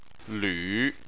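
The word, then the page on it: lv: tone 3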